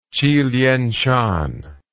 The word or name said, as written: Chilien Shan